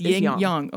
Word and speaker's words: yin yang: It's A's are not pronounced A right. in China. It's ah. – Ah. – Like the Yangtze River. It's not the Yangtze River. It's the Yangtze River.